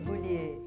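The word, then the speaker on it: Gaulier: Formada pelo Teatro Escola Macunaíma, pela Escola Internacional de Teatro, como pronuncia? École Philippe Goulier? – École Goulier.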